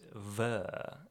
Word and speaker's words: bheur: Speaking of freezing, the next one we got is Ver Hags, but it's spelled B-H-E-U-R, but it's a Gaelic word, so you pronounce it ver.